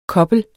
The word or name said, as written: kobler